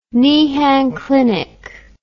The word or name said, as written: Niehan clinic